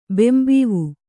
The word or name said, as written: ಬಾಂಬಾವು